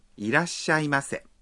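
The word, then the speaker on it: Irashaimase (いらっしゃいませ): A propósito, al pronunciar hay que contener la respiración un instante entre da y sha. Irashaimase.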